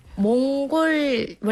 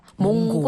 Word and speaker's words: 몽골: L 뭐 L이 들어가요. 몽골이야. 그런데 예. – 이거를 이게 40 때 이후 한국 분들이 몽고라고 발음해요. – 몽고. – 몽골이 네. 아닌 몽고. – 네. – 몽고, 몽고, 몽고예요.